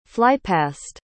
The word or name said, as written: flypast